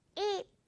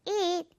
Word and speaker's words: eat: 이거 여러분 정색해서 발음하면 그냥 대명사 그 it처럼 들려요. it 아니고 지금 이거는 미소 발음, 미소 미소. 그래서 eat 이렇게 발음이 돼요. 길게도 발음해줘야 돼요.